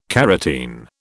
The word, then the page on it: carotene: British English